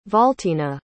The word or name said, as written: valtina